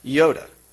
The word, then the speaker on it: Joda: And he was the son of Joda, okay? A name that possibly comes from Joida or Jehoida from the Old Testament. But in the Greek, Joda is pronounced Yoda.